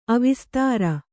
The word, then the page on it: ಅವಿಸ್ತಾರ: Kannada